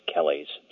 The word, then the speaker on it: Kelly: That's because there are a lot of Kellys around, and there are even a lot of David Kellys.